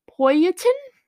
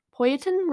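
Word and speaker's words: poitín: Also, anyone at that wedding probably would have been drinking this drink called, oh no, Poyatin? It's spelled P-O-I-T-I-N. Poyatin, we're gonna go with that,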